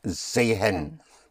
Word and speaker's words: sehen: Actually, it's spelled S-E-H-E-N. Sehen. But pronounced sehen.